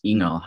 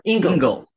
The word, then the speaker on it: Ingall: And today I have someone who's been in through so many different genres of photography, and I'm so excited to introduce Jules Ingall.